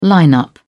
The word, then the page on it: line up: British English